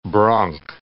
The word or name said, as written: bronc